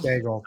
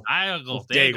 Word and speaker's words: Daigle: A-I-G-L-E. It might just Daigle. Be Daigle. – Diagle. Yeah, it's Daigle. – Daigle. – Daigle. I'm pretty sure it's just Daigle. – Daigle, even.